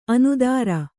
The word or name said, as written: ಅನುತ್ತರ